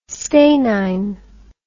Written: stanine

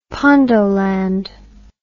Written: pondoland

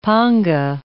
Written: panga

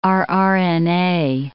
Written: rRNA

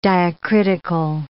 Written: diacritical

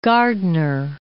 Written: Gardner

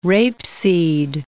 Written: rapeseed

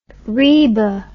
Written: reba

